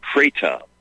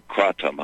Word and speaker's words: kratom: It's called Kratom, or Kratom, however you want to pronounce it. I say K-R-A-T-O-M. So for my buddy Fred in Iowa, or Iowa Fred, Kratom.